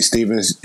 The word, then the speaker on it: Stephens: My name is Rasheed Stevens.